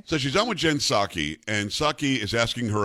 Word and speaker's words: Psaki: Uh, she's on with Jen Psaki. Is it Psaki? – No, the P is silent.